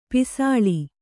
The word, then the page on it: ಪಸಲು: pasalu